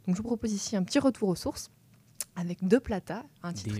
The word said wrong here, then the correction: Des Plata: Des Plata. – Des Plata, pardon, je me fais reprendre sur ma prononciation.